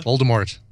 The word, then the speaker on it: Voldemort: Volva? – Voldemort. – Oh, I said Volva.